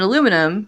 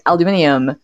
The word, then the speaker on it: aluminium: And what's funny about that is that the British pronunciation is only aluminium because they wanted it to sound like the other elements. Like, they don't add an I to it. Yeah. It's literally only like that because they had, like, you know, lithium and beryllium and all these other, like, elements that had that im sound at the end.